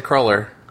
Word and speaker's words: cruller: Well, it's spelled, I think, traditionally, it's C-R-U-L-L-E-R. – Yep, that's right. – Okay. So. – And I've always said cruller. – I've always said cruller, too.